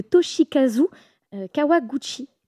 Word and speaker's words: Toshikazu Kawaguchi: Et ça s'appelle « Tant que le café est encore chaud » de Toshikazu Kawaguchi.